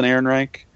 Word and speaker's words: Ehrenreich: Alden, but you pronounced it good earlier. Alden Ehrenreich. I think that's what we decided, right? Alden Ehrenreich? – Sounds right, yeah.